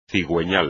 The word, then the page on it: cigüeñal: espagnol